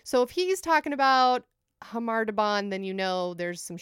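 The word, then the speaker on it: Khamar-Daban: But as we just heard from the song, it's pronounced Khamar Daban. – Right. – Like an H noise.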